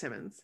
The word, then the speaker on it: Timmons: I'm pretty sure it's Timmons. – I always want to say Timones, like Timon and Pumbaa. But there's two M's there.